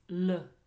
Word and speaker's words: le: Now, him or it in this situation is le, L-E, like the article le.